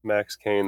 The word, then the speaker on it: Max Kane: Hello, said it correctly? – Everybody. Max Kane, that's correct.